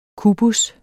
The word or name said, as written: kubus